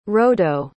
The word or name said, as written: rodo